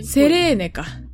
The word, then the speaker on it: セレーネ: あ、セイレーンじゃなかった。読み方が違うかも。 – セレーン。でもセイレーン。 – セレーネか。 – セレーネ。 – セレーネでした。